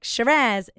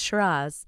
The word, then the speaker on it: Shiraz: And when you say Shiraz, okay, you don't say it like Shiraz, it's Shiraz. But it is grown in Australia mostly and South Africa.